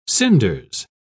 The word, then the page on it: cinders: British English